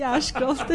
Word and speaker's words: Ashcroft: Ashcroft, come si dice? Ashcroft. – Così, Ashcroft, hai detto bene.